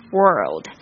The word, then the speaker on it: world: 那就跟这个 WORD 没有什么差别，特别注意一下这个 L 的发音，舌头要翘起来。 World, all over the world.